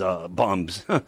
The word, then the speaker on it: Dharma Bums: That is from the 1988 debut from Dharma Buns. Bums. Bums, I think I said it right. Not Buns, Bums.